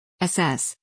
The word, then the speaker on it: Assess: Assess, accent on the second syllable, please, is patterned after COFS.